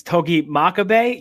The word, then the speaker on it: Togi Makabe: It's Togi Makabe.